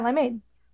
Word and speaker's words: limeade: It sounds most natural when the emphasis is on the second syllable. – So limeade. – Yeah, limeade.